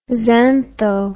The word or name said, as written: xantho